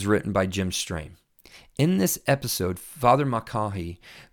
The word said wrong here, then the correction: Mulcahy: I hope I'm saying that right, Mulcahy, there you go, I probably said it right now.